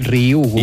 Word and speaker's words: Ryugu: Ryugu. – Ryugu. – És molt fàcil, com una I, Ryugu.